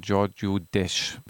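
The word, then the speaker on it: Gheorghiu-Dej: Doftana in dem Gefängnis den äh, einen Menschen kennen, der ihn dann einige Jahrzehnte fast begleiten wird, nämlich Herrn Giorgio Desch, wenn ich das richtig ausspreche, hatten Sie gesagt, ne? – Perf perfekt aus.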